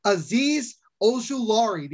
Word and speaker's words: Azeez Ojulari: Azeez Ojulari, the uh, the outside linebacker out you of got Georgia.